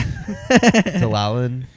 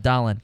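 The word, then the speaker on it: Dahlin: Dalalan? Was that how you say it? – Dalin. Rasmus Dalin.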